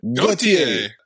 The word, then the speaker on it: Gauthier: Gauthier? I don't know how you say it. – Gauthier. Like the song.